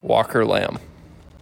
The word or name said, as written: WALKER LAMB